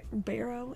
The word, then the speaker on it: Barrow: He also lost his New York property, including the carriage house, 17 Barrow Street. Is that Barrow? Am I saying that right? – Sure.